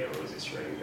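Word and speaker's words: Rozenstruik: Is it pronounced Rosenstruik? – Yeah, Rosenstruik. That's, I guess, the American way to say it.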